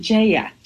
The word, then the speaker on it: Jaya: It's actually Jaya, but very close. – Fantastic. Thank you for that correction, Jaya. Jaya Liebowitz.